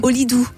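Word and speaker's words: Holidu: J'ai bien prononcé ? – Olidou, on dit.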